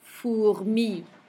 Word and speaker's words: fourmi: OU is pronounced OU. So let's do those two words again. Souris. Fourmi.